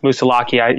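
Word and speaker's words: Moosilauke: I can, you know, the day that I hiked over Moosalock, or it's pronounced Moosalaki, Mount Moosalock, which is the last mountain in the White Mountains before I, you know, successfully got through those.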